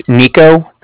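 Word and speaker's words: Niko: It's Nico. That's N-I-K-O. And then calling with the Bank of Cardiff, and that's spelled C-A-R-D-I-F-F, as in Frank Frank.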